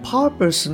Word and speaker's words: purpose: で、まあ、ちょっと補足なんですが、えー、 purpose の 2 つ目の P ですね。これはアクセント音節ではないので、あのそこまで大きくはなってないです。